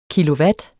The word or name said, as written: kilowatt